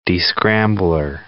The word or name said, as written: descrambler